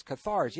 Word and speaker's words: Cathars: Now, the Cathar, is that how you pronounce it? Qatar? – Cathars, yes. Yeah, I really enjoyed reading about... – Like catharsis is a cleansing.